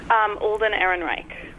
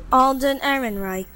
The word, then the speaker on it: Alden Ehrenreich: How do you pronounce his name? – Alden Ehrenreich. – Right, we've YouTubed it. – Alden Ehrenreich. – Yeah, you're right.